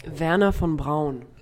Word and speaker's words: Wernher von Braun: Is it Wernher von Braun? Is that a better pronunciation? – Wernher von Braun. – Nice, okay. Sorry, I'm going to call him Wernher von Braun because that's how he's known in the States.